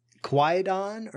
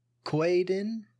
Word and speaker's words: Kwaidan: It's Quaidon or Quaidin or something like that. K W A I D A N is how it's spelled.